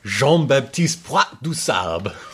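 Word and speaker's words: Jean-Baptiste Point du Sable: Jean-Baptiste Poit-du-Sable.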